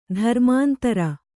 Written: ದುರ್ಮಂತ್ರ

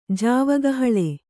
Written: ಜಾವಗಹಳೆ

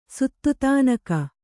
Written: ಸುತ್ತುತಾನಕ

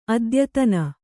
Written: ಅತ್ಯಾಧಾನ

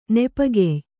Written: ನೆಪಗೆಯ್

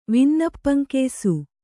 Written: ವಿನ್ನಪ್ಪಂಕೆಯ್ಸು

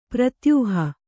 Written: ಪ್ರತ್ಯೂಹ

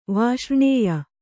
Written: ವಾಷ್ರ್ಣೇಯ

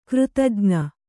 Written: ಕೃತಜ್ಞ

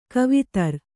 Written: ಕವಿತರು

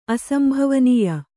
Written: ಅಸಂಭವನೀಯ

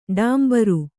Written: ಟೊಂಬರ

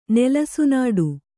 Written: ನೆಲಸುನಾಡು

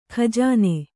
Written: ಗಜನಿ

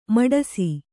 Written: ಮೀಟಿಸು

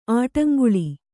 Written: ಆಡುಂಗುಳಿ

